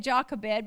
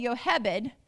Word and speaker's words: Jochebed: Jochebed, however you say her name, we always said Jochebed, but it's really Jochebed, was the mother of Moses who would deliver a deliverer.